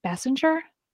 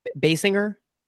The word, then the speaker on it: Basinger: Kiss Kim Basinger. Basinger? – Basinger. – Basinger. There we go. I knew I was saying her name wrong.